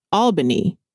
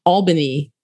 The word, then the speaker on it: Albany: I pronounce that Albany when I first saw it. I was like, oh, okay, you're from Albany, Georgia. And he was like, that's not where I'm from. Mm-mm. He was Mm-mm. like, I'm from Albany is how. – Albany. – Okay, mm-hmm. that's how he told me to say it.